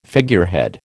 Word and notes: figurehead: American English

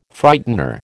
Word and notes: Frightener: American English

